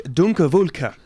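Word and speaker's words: Donkerwolke: Luke Dunkerwolke. Dunkerwolke. – That sounds about right. – Yes. – It sounds like what it looks like.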